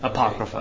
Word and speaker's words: Apocrypha: Apocrypha.